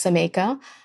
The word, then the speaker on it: Xaymaca: It's spelled X-A-Y-M-A-C-A, but it's pronounced Zameka.